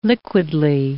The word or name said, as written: liquidly